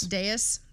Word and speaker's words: dais: Is it pronounced dais? – I think it's dais. – Dais? Cool. – Like deus ex machina, dais. – Okay, dais. Great.